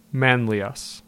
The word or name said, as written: Manlius